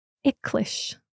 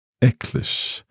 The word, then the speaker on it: eaglais: Icklish. – Icklish. Can you spell it for me? – E-A-G-L-A-I-S.